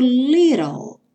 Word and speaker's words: little: O little, o T vai ter esse som do R. E o L vai encerrar o som, então, lá no céu da boca, porque o E final não é pronunciado. Então, little, little prayer, como se fosse um E com acento agudo.